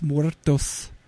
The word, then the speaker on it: Muertos: One was called Vampires Los Muertos, and John Bon Jovi was the main vampire hunter in it.